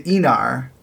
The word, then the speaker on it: Aenar: So uh, next time we're going to be talking about uh, the it's I believe it's pronounced the Aenar, although it's spelled A E N A R, and it is uh, season four.